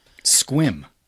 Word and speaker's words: Sequim: Nope, they are from Sequim, Washington. – Squim. – It's S-E-Q.